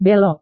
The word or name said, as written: belok